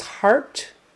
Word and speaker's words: heart: The middle sound in heart is the same as the middle sound in hard. It's an A-R, vocalic R sound. Even though it's spelled with an E-A-R, it's just, forget the E. It's A-R.